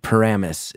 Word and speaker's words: Paramus: Paramus is the way to pronounce it.